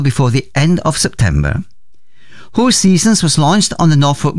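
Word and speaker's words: Hoseasons: Ho Seasons, as it is pronounced,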